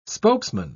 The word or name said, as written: spokesman